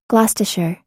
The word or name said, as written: gloucestershire